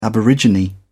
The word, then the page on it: aborigine: British English